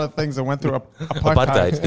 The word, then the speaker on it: apartheid: It blows my mind because we've been saying it wrong the whole time. Apartheid. It's like something you order from KFC, a thigh.